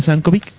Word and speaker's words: Sienkiewicz: ¿Cómo se pronuncia? – Sienkiewicz. – Uy, ok. – Sí, está cañón. – Es que se escribe Sienkiewicz. Sienkiewicz. – Mucha gente dice Sienkiewicz. – Sienkiewicz. – Es muy difícil de decir.